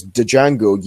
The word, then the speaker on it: Django: Who, you know, even shoots Walton Goggins in the cock because he says, "Django, you uppity son of a bitch," and he shoots him in the cock and says, "The D is silent, hillbilly."